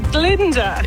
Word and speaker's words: Glinda: It rhymes with Tinder. – Oh, Tinder. – Yes. Ah, yes. It's Glinda.